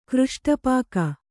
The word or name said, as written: ಕೃಷ್ಟಪಾಕ